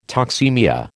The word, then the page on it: toxaemia: American English